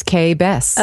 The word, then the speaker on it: Kay Bess: It's Kay Bess.